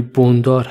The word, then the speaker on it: بندار: تلفظ صحیح بابقلی بابقلی بندار هستش که من به اشتباه بندار